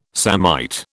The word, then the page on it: samite: British English